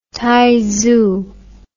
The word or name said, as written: taizu